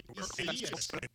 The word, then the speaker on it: Isaias: The correct pronunciation of the hurricane is Hurricane Isaias.